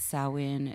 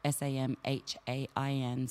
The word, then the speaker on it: Samhain: Uh, it's uh, it's Samhain. It's um, you know, which is is spelt S A M H A I N, so people get it confused, but it is actually pronounced Samhain.